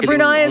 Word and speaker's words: Brunei: Yeah, Brunei is what we call it. – Yeah, Brunei.